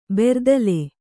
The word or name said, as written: ಬರ್ದಿಲ